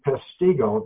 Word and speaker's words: Peshtigo: a fire was started in Peshtigo. That's a tough word for any language, but I'll spell it. P-E-S-H-T-I-G-O. Peshtigo, Wisconsin was burned.